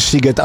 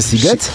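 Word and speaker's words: Sziget: kojarzy, że pisze się Shiget, a wymawia się Siget? Siget.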